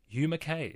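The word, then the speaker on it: Hugh Mackay: You might know him as Hugh McKay. We've heard it tonight from him. Hugh McKay is the proper pronunciation.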